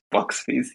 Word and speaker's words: Boxbies: Sorry, I didn't pronounce it right. I've been calling you guys boobies. It's Boxbies.